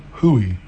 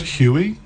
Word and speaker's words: hui: I've heard Huey. – Okay. – Yeah, and I didn't like that.